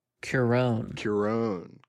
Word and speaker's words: Cuarón: Cuarón.